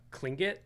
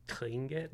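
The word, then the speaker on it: Tlingit: So I was like, okay, what if I took this like, uh, um, it's uh, Klingit, I think is how you're supposed to say it. Mm-hmm. It's spelled T L I, but it's kind of like Klingit, Yeah.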